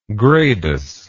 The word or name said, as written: gradus